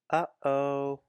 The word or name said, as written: Uh-oh